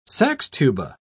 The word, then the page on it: saxtuba: British English